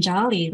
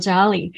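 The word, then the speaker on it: jaali: Beautiful cut fabric that can allow, which acts like a modern jolly, like a textile jolly, where people will still have the privacy, but have the light coming in to help their circadian rhythm.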